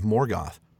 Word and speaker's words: Morgoth: And we even have the weird contradiction of Morgoth. Two O's, but each O is pronounced differently.